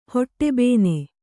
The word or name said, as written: ಹೊಟ್ಟೆಬೇನೆ